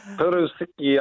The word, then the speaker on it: furoshiki: Let's start with um, the Japanese tradition of furoshiki. – Oh, very good. I was wondering if you would pronounce that on Right? – furoshiki.